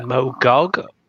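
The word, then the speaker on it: Mog'ok: Mogok? With a hard G. – M O G apostrophe O K. Mogok, right.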